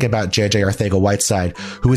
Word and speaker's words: Arcega-Whiteside: Bill, a feather in your cap is how you correctly pronounced Arcega-Whiteside.